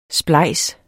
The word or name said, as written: splejs